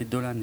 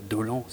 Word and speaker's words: Dolan: Alors, Mommy de Xavier Dolan, qu'il faut faire très attention à bien prononcer Dolan et pas Dolan, sinon on se fait insulter sur Twitter par le principal intéressé.